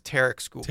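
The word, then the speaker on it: Tarik Skubal: Tarek Skubal? How do you pronounce his first name? – Yeah, I think it's Tarek Skubal.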